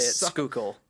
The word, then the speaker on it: Schuylkill: Maybe it was the water coming out of like the Schuylkill River in Philly. – Schuylkill. – The Schuylk. – Schuylk's water. – That's how you say it. Schuylkill.